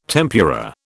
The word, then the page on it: tempura: British English